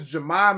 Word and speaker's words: Ghiman: My name is Jamai McKinney. Name is spelled G-H-I-M-A-N. Last name M-C-K-I-N-N-E-Y.